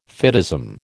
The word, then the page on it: fideism: American English